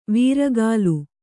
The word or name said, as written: ವಾರಿಕಲ್